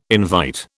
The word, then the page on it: invite: British English